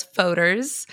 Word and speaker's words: Fodor's: Which one is it? – I get all different pronunciations. So it's Fodors.